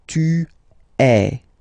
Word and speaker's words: es: Okay, and for tu, then you've got this combination of letters. Okay, but then basically the sound you will pronounce or you will produce is e. Okay, tu es.